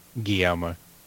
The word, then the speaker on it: Guillermo: Say it again. – Guillermo. – Guillermo del Toro. – You, yeah, you don't pronounce the L.